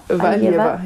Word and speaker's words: Valieva: Valieva. Valieva, ja, das hat jeder anders ausgesprochen, aber Valieva, ja.